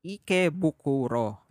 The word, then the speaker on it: Ikebukuro: When he was 25 years old, he moved to Tokyo in an area called Ikebukuro. Did I say that correctly? Ikebukuro.